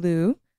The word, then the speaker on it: Liu: Nicole Kidman, Andrew Garfield, Idris Elba, Kristen Stewart, Simu Lee, pronounced that wrong. Simu Liu, Benedict Cumberbatch, MJ Rodriguez, and Penelope Cruz.